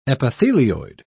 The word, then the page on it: epithelioid: British English